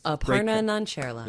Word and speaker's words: Aparna Nancherla: Aparna Nancherla.